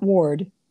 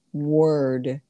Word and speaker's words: word: Not ward, word.